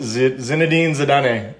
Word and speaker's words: Zinedine Zidane: Zinedine Zidane.